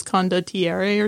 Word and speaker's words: Condottiere: I've been going like, oh, it's called Condottiere, but it's actually Italian, so it's Condottiere or something.